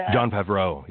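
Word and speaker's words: Jon Favreau: I think the one person who might have known was John Favreau. You know, am I saying his name correctly? The one that directed... – John Favreau, yeah.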